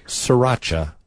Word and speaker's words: Sriracha: Sriracha. – Well, it's a sauce that everybody says wrong because it should be sriracha because there's an R in there. – S-R-I-R-A-C-H-A.